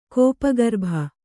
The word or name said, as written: ಕೋಪಗರ್ಭ